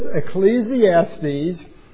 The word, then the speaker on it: Ecclesiastes: But it is Ecclesiastes.